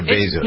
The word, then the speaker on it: Bezos: Do you pronounce it Bezos or Bezos? – No, it's not how I pronounce it.